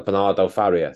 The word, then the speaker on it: Bernardo Faria: Because you know, Bernardo Faria, is that how you say it? – Yeah.